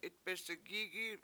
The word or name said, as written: itbesigIgi7